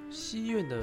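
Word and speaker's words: 西院: 第六个是右京区的西苑，西苑的发音“赛”和比赛的“赛”的发音是一样的。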